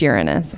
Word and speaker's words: Uranus: On this date, 1781, William Herschel discovers Uranus. – It's Uranus, but yeah. – No, I like to pronounce it the way I like it.